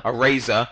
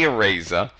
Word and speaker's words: Areza: And then the week after that, we're very excited to have Ellie Araza. – Areza. I even spelled it for you phonetically. – I screw up the guests' names all the time. Ellie Areza.